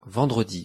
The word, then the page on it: vendredi: French